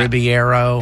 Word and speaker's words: Ribeiro: Ribeiro.